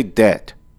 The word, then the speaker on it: debt: You have that fucking B in the middle. I mean, you say debt. But D E B T. So what? The B is silent?